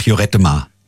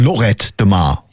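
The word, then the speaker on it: Lloret de Mar: Nee, noch nicht, aber ich glaube, es geht eine Woche nach Jorettemar. – Jorettemar, sagt er. Du meinst Lorettemar. – Ja, aber zwei L's werden da halt ausgesprochen wie J, ja? Das heißt ja auch Paella und nicht Paella.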